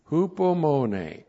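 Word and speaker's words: Hupomone (ὑπομονή): Hupomone. H-U-P-O-M-O-N. Long E. Hupomone.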